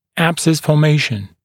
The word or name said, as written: abscess formation